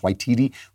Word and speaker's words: Waititi: One of the people who won an Oscar was Taika Waititi, I think it's pronounced Waititi, who made that Jojo Rabbit, which was a comedy about the Nazis.